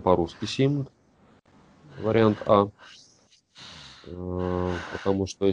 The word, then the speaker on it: симд: Симк. – Нет, на конце Д, Дмитрий.